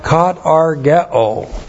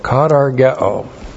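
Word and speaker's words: katargeō: And then we have katargao. That's K-A-T-A-R-G-E long O. Katargao.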